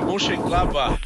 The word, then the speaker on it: Mönchengladbach: Grupo C aqui é temos Barcelona, Borussia, Mönchengladbach. Eu nunca vou saber falar isso direito. Se eu falei errado, alguém me corrige aí, fica à vontade. Celtic e Manchester City. – Cara, eu. É o Borussia Mönchengladbach.